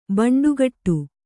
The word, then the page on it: ಭಂಡಗೇಡು: bhaṇḍagēḍu